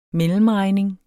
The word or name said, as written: mellemregning